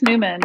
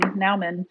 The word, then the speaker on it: Nauman: Bruce Newman, Nauman.